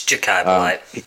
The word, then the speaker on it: Jachai Polite: Jachai Polite. I never have gotten nailed down exactly how to pronounce that. – It's Jachai Polite.